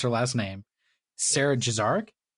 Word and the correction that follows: Gazarek: right? – I always thought it was Gazarek. – Gazarek, you're most likely right.